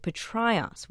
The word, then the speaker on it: Petraeus: Professor King said Petraeus, which is how the ancient Greeks would have said that combo of A and E. Petraeus, Petraeus, either way, the name was common in northern Greece, especially in Thessaly, says King.